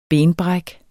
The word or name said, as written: benbræk